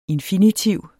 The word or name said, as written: infinitiv